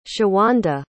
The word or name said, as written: shawanda